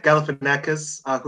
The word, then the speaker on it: Galifianakis: Like, also, Zach Galifianakis, who plays Jack. I don't know if I pronounced his name properly. – Yeah. That's how you pronounce it.